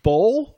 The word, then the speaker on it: boul: So it was a Bull? Bull? – It's pronounced like bull. – Bull, wow. – But it's spelled. – Minute bull? – Spelled B-O-U-L.